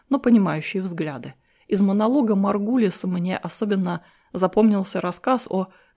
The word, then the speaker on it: Маргулис: Услышав название протоктисты вместо привычного протисты, простейшие, я поинтересовался у собеседницы, не знакома ли она с Лин Маргелис или Маргалис, которая пустила в оборот этот термин. Я не знал, как правильно произносится эта фамилия. Маргулис, поправила она, это я.